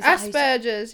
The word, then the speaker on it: Asperger's: I don't know if I'm saying it right, but your Asperger's. – Is Asperger's, yeah.